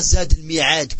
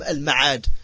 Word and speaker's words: al-Ma'ad: Et on ne dit pas Zadul Mi'ad, comme certains disent. C'est une erreur. Parce que Al-Mi'ad ne s'écrit pas comme Al-Ma'ad. Al-Ma'ad, après le mim, directement on a le Ayn. Alors qu'Al-Mi'ad, entre le mim et le Ayn, il y a un Ya.